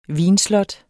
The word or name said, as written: vinslot